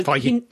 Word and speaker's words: Feige: Feige. – What? – Feige. – Is that how you pronounce it? – Feige. – Well, it's spelled like beige, but with an F.